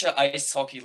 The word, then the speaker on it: Eishockey: So, Deutsche Eishockey League. So, ice hockey in German is EIS hockey. – Okay. – That's how you say it in Deutsch, obviously, Deutschland.